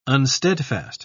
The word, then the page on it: unsteadfast: British English